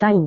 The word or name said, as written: tahu